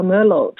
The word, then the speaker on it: Merlot: 是，还有一个呢叫 Merlot 呢，它的拼写其实是 Merlot，那个 T 其实不发音，所以呢叫 Merlot，中文名应该是叫米洛吧，昵称就没有了，因为 Merlot 如果还在缩的话就缩没了。